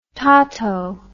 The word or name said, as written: tauto